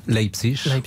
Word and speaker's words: Leipzig: PSG Leipzig, parce que paraît-il que je l'ai mal prononcé. C'est comme ça qu'on dit ? – Leipzig.